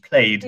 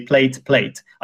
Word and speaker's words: played: Essentially, it ends with d sound, which is a voiced sound. So you have to pronounce it in English, played. – Yeah, and a lot of Czech learners would just pronounce this in the same way. So they would just say plate.